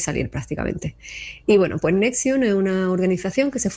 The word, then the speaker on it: NXIVM: que se llama Nexium, se escribe Nxivm, y digo cómo se escribe